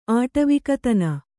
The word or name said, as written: ಆಟವಿಕತನ